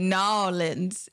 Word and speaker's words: New Orleans: That's how they say it, New Orleans. It's not two words, it's one.